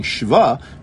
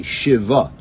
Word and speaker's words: shva: For example, I keep saying shva, but according to the rules of biblical grammar, it's actually a shiva, right? It's a two-syllable word.